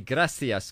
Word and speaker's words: gracias: Or perhaps using Argentinian pronunciation, I should say gracias, because that's how they say it in Argentina.